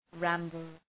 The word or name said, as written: ramble